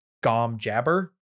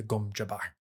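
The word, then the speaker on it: gom jabbar: I was very happy to see no one pronounced it gom jabber. The Reverend Mother didn't say that, because that is, of course, just an inherently funny thing to say. – It is. – She instead pronounced it, "I hold at your neck the gum jabbar."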